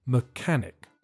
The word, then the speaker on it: mechanic: The pronunciation is not very difficult, but the first syllable is weak and there's a silent H. Mechanic.